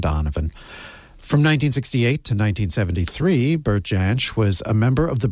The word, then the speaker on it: Bert Jansch: Bert Jansch, and yes, I did check the pronunciation of that name, was one of the most influential guitarists of the 1960s.